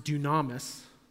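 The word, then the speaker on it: dunamis (δύναμις): Okay, and then this third word, it looks like dynamis, it's pronounced dunamis.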